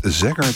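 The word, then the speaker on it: Zager: En daarvoor, ja, de correcte uitspraak schijnt Zegger te zijn, maar wij zeggen gewoon lekker eigenwijs de Michael Zegger Band met Let's All Chant.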